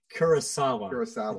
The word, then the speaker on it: Kurosawa: Akira Kurosawa? Did I say that right? – Kurosawa. I think is what you were trying to say.